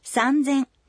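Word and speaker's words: сандзэн (三千): Но будьте внимательны, произношение в некоторых случаях тоже меняется. 3000 – «сандзэн». 8000 – хассэн.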